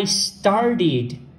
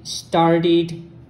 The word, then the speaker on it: started: Neste caso, o start é um verbo, assim como want, que você, a pronúncia do ED vai ser id, started, wanted, que na verdade quando fala rápido fica wanted, então started, só que não é ed, tá? É id, é tipo um I entre I e id.